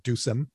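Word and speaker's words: Ducem: He meets with Dusem Bar, who is I don't know if anybody has a different pronunciation of that name, but I always called it Dusem with a soft C.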